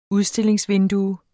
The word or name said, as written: udstillingsvindue